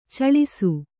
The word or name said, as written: ಛಳಿಸು